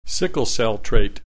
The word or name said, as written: sickle-cell trait